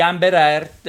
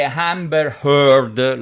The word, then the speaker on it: Amber Heard: Parlo di Amber Heard e Johnny Depp. Cos'è successo? Che Amber Heard, lo pronuncio meglio, Amber Heard e Johnny Depp hanno divorziato,